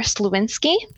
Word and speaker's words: Sliwinski: So we have Brittney Hamilton on the show, and we also have Heather Sliwinski.